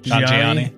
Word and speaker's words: Nanjiani: Kumail uh, Nanjiani. – Nanjiani.